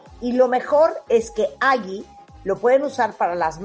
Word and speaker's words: Aggi: Es Agi y se escribe A-G-G-I .mx ahí es la página,